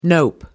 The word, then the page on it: nope: American English